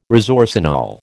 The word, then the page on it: resorcinol: American English